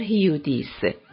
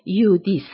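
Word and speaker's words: Hudis: پیتر هیودیس. پیتر هیودیس، تلفظ درست نام وی یودیس است.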